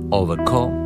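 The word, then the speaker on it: Overgaauw: Εδώ από το ντεμπούτο άλμπου με τίτλο Don't Disturb στην Ολλανδική CBS του Wim Overco. Έτσι προφέρεται ένα το επίθετο του, γράφεται Over όπως το ξανά και μετά GAAUW.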